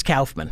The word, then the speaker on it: Kaufman: Well, so Kaufman is how it ought to be pronounced in English. You know, A-U is aw. – Aw. – But in German... it's Kauf.